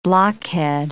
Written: blockhead

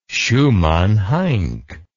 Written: schumann heink

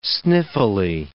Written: sniffily

